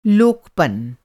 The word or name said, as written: लोकपन